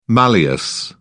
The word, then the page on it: malleus: British English